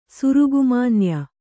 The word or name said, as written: ಸುರುಗುಮಾನ್ಯ